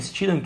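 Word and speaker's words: Stidham: Steedham que se pronuncia assim mesmo.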